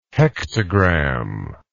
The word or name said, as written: hektogram